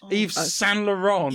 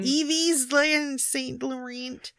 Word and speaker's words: Yves Saint Laurent: Yves Saint Laurent has launched Yves Saint Laurent. – Yves Saint Laurent.